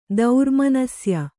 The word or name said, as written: ದೌರ್ಮನಸ್ಯ